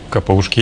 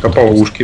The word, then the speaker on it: капаушки: Капаушки, или капаушки, я не знаю. – Капаушки, да. – Капаушки. Ударение, да.